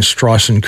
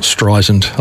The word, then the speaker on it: Streisand: So those of you who are pedants know I'm pronouncing Streisand correctly now. It's not Streisand.